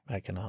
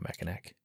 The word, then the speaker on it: Mackinac: Mackinac. I know. I like to call it Mackinac. – A lot of people who don't know the proper pronunciation say that.